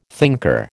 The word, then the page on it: thinker: American English